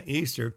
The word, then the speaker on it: Ishtar: Now, Easter comes from Ishtar, I-S-H-T-A-R, which is pronounced Easter, which is the daughter of Tammuz, a queen of heaven.